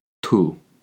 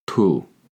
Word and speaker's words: tú: Tú. Tú. T-U, accent on the U. An easy way to remember this is that tú rhymes with you. To say in Spanish, you are, is tú eres. Tú eres. Spelled T-U space E-R-E-S.